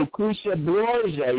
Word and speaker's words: Lucretia Borgia: I Believe also was a one-woman play called The Story of Lucretia Barza. Did I pronounce it right? – Lucretia Borgia, yes.